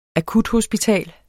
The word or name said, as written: akuthospital